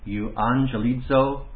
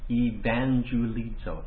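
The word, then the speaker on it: euangelizo (εὐαγγελίζω): That word is, let's see if I can pronounce it, euangelizo logos. Euangelizo logos. Evangelizo, if you want to get it. It's euangelizo logos.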